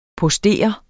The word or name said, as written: postere